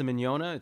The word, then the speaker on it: Mignogna: My last name, when we were kids, we started in the entertainment industry as actors and musicians and things, and nobody could pronounce the Mignogna. It's spelled M-I-G-N-O-G-N-A. – Mignogna, the G's are silent. – Spelled. – You know, like filet mignon. We're Italian.